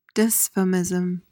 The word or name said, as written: dysphemism